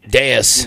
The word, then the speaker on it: Deus: It's the Deuce Cafe, I believe it is. The owner, maybe, possibly. It's sponsored by Deuce, but it's like totally just... – Deus. You know, Deus Ex Machina is the proper way to say that.